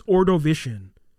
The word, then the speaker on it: Ordovician: So the cave is mostly formed from limestone and began forming about 448 million years ago during the Ordovician period, I believe is how you say it. I think it's Ordovician period. Yeah.